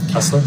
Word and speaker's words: Kessler: Kessler. – Please, spell it. – K-E-S-S-L-E-R.